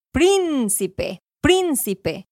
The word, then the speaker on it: Príncipe: El acento más fuerte va al principio. es una palabra que se parece. Al inicio, escucha. Príncipe, príncipe.